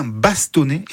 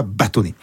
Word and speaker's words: bastonner: D'ailleurs, dans son dictionnaire provençal et français de 1728, Sauveur Adrien Pélas prescrivait prononcer l'est, donc dites bien bastonner et pas bâtonner.